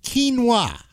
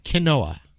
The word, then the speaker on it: quinoa: So it's quinoa, not quinoa.